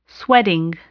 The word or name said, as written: sweating